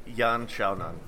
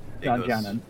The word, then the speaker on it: Yan Xiaonan: Yan Xiaonan. Yan is the last name, but Yan Xiaonan. Okay. – Goes first for the Eastern languages.